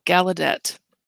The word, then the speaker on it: Gallaudet: The accent on the middle syllable, Gallaudet.